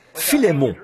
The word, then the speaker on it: Philémon: Donc, quelqu'un a dit, là-bas, au fond, Philémon, ce qui, je pense, est vraiment la bonne prononciation.